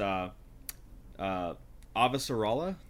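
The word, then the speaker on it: Avasarala: Yeah, and it's Ava Sarala.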